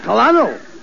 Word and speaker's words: Colonel: No, you see, that word is pronounced Colonel. – Colonel? – Yes, Colonel. Where did he fight? – Oh, my uncle. He fought with the original 13 Colonelies. – No, Costello, that's Colonies. – Oh, Colonies. You said it was Colonelies. – No. – Colonelies. Now, make up your mind. – Look, it's very simple. In military parlance, C-O-L is pronounced Cur, as Cur. – In Colonel.